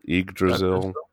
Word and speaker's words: Yggdrasil: How do you pronounce this? – I think it's Yggdrasil. – Yggdrasil.